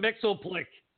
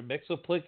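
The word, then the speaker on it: Mxyzptlk: Mitzelplick, however you're pronouncing it, was on Supergirl a couple weeks ago, and they pronounced every single letter in his name. – I know. I saw that one. It was like, Mix's Pitlick. I was like, what? – What? I was like, huh? Like, you don't pronounce every letter?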